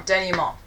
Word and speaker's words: denouement: That's why it's neat to have the, you know, the typical story structure of exposition, rising action, climax, that last word that's kind of Frenchish that I can never pronounce. – Denouement. – That's the one. – Denouement.